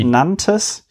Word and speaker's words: Nantes: Spricht man das Nantes aus in Frankreich? – Eh, oui. – Nantes.